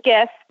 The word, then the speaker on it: GIF: Is it GIF or JIF? – Oh my goodness. We love to say hard G's for hard G's. So it is GIF, definitively GIF for GIF.